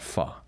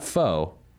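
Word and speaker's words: phở: That is P-H-O, Peter Home Office. We call it Pho. Because if you're pronouncing the noodle soup dish correctly, it's not Pho, it's Pho.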